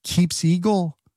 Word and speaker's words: Keepseagle: Now, I don't even remember this, but the Justice Department pointed to the Obama administration's creation of the, I think it's pronounced Keeps Eagle. It's one word, K-E-E-P-S-E-A-G-L-E.